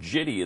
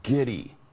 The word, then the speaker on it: giddy: How does a producer not go to him after the fourth or fifth or sixth time he said jitty instead of giddy and say, Al, the word is giddy, okay? It's a hard G sound.